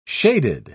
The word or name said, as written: shaded